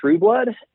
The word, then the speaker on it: Trueblood: Yeah, so on social media, it is at Ben Trueblood, and the last name is spelled just like it sounds, T-R-U-E-B-L-O-O-D.